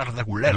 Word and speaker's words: Arda Güler: Arda Güler, como se debe pronunciar, uh-huh. Arda Güler.